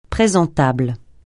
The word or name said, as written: présentable